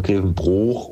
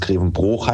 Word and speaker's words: Grevenbroich: Ich höre gerade eure Folge mit dem Tim und da geht es um Grevenbroich oder was heißt es, Grevenbroich? Korrekterweise muss es Grevenbroich heißen. Das ist ein Dehnungszeichen.